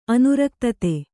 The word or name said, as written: ಅನುರಕ್ತತೆ